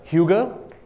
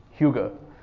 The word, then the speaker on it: hygge: It's spelled H-Y-G-G-E, hygge. But nobody can pronounce it correctly. When I go to Denmark, they don't understand what I'm saying. It's difficult to pronounce, but it's something like hygge, hygge, hygge, something like that. H-Y-G-G-E.